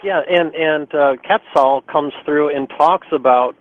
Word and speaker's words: Quetzal: And it's actually pronounced, now we have differences in how we pronounce things, but it's Quetzal, which okay.